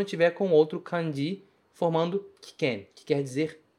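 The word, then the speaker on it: kiken (危険): A palavra é kiken, kiken. E o kanji de abunai aparece novamente aqui. Só que nesse kanji, nessa palavra, o primeiro kanji se pronuncia ki.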